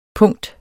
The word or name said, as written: punkt